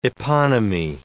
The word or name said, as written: eponymy